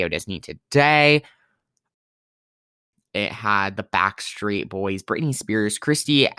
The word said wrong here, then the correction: Christina: Christina Aguilera.